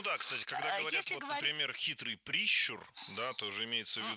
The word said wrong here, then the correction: прищур: Не прищур, а прищур. Ударение в этом слове ставим на второй слог. Да там его и оставляем во всех формах.